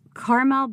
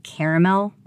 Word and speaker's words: Carmel: I'll never forget the time I read this very wealthy kid's script and pronounced it it was Carmel by the Sea, but I pronounced it Caramel by the Sea, and everybody laughed at me and corrected me.